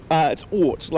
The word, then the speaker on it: Oort: Am I saying that right, or is it O-O-R-T? I've not been able to work it out. – Yeah, so it's Oort, like Oort. So based on the Oort cloud, which is like the outer past the planets in the solar system.